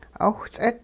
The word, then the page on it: 'owhts'it: hupa